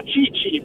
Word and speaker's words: Tite: È chiaro, l'allenatore del Brasile, che si chiama Tite, in Brasile lo chiamano Cici, però insomma diventa a volte anche ridondante, no? – Ronaldinho, se no diventa una cosa, esatto.